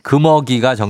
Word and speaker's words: 금어기: 이것 어류 따위의 번식과 보호를 위해 고기잡이를 하지 못하도록 하는 일정한 기간 금어기가 정답이었습니다. 섭섭이님 처음에 왜 금오기라고 그랬어요? 아, 예, 걷다가 잘못 발음 나와갖고, 어. 아, 긴장을 해갖고 발음이 잘못 났네요.